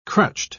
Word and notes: crutched: British English

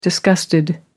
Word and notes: disgusted: American English

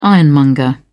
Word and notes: ironmonger: British English

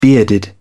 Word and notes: bearded: British English

